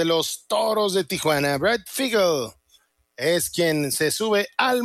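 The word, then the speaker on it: Feigl: Brady Figel, Figel, para correcta pronunciación.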